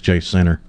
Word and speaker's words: Jacinto: It's spelled J-A-C-I-N-T-O. Now, honestly, the A could be long or short.